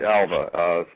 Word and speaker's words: Alva: Just my name, Pete Alva. Spell that A-L-V as in Victor A.